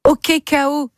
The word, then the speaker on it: OKKO: OK KO, c'est le nom de l'album, ça s'écrit O-K-K-O, on entend le KO,